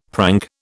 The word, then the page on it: prank: British English